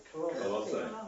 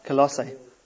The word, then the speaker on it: Colossae: He said, notice that they are in Christ, but they are at Colossae. I'm going to say that wrong every single time. How is it? – Colossae. – Colossae.